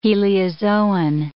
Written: heliozoan

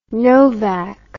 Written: novak

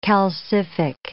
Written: calcific

